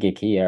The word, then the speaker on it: IKEA: I think IKEA are like the best. Notice as well, I'm pronouncing it the Swedish way, like I do with Acne. IKEA.